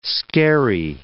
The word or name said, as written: skerry